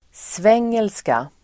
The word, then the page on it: svengelska: [sv'eng:elska]